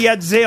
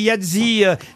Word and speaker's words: Yahtzee: Alors on dit Yadze en France, ouais. On dit Yadzi, ça dépend comment on le prononce évidemment, mais voilà. on dit effectivement Yadzi aux États-Unis, Yadze. Moi j'ai toujours dit Yadze, mais peut-être je me trompais à l'époque. – Moi, Yadzi, mais en tout cas ça s'écrit Y-A-H-T-Z-E-E.